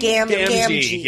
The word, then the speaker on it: Gamgee: Gamgee.